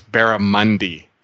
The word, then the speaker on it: barramundi: I was told last week that I mispronounced the native fish. It's barramundi.